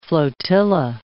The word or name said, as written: flotilla